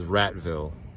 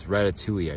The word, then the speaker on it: Ratatouille: When you read it, it looks like it says Ratville. But it's Ratatouille, I guess.